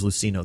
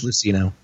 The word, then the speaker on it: Luceno: It's James Luceno that wrote it, or Lucino. Mm-hmm. I don't know how you say it. – I think it's Luceno.